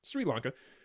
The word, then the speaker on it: Sri Lanka: So I didn't know if it was like Sir, but Sri Lanka.